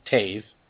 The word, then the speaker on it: Toews: Number two is Toews, which is Tave. – It's T-O-E-W-S.